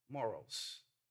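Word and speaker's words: moros (μωρός): it's pronounced moros, moros in the original language, and it's an expression of abuse.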